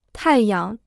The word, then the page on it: 太阳: tài yang